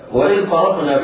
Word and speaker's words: فرقنا: وإذ فرقنا بكم البحر فأنجيناكم وأغرقنا آل، وإذ فرقنا الفاء هنا مرققة ضم مجاورتها الراء والقاف المفخمتان. وإذ فرقنا، لا يسقط اللسان وتفخم الفاء وتقول وإذ فرقنا، هذا نطق خطأ.